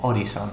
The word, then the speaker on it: Horizon: Pero ahora que lo pienso, ¿se pronunciará Horizon o Horizon o algo así? Mm. Horizon. – Horizon, es verdad, no lo he pronunciado bien.